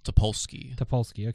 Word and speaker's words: Topolsky: So the other night, I was, you know, checking my Twitter feed, and I see this post from Joshua Topolowski. How do you say that? – Topolsky. – Okay, I was right the first time.